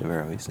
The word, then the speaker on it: Averroes: Averroes. Ibn Rushd Averroes. Yeah. – Maimonides Averroes and Ibn Arabi. And uh, I, I was in Cordoba in May with my wife, saw the statues of Maimonides and Averroes.